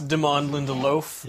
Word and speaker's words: Damon Lindelof: No, it's Damon Lindelof.